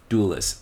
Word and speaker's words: Dulles: Is it Dulles or Durless? – Dulles. It's Dulles. – D-U-L-L-E-S. I've been saying it wrong.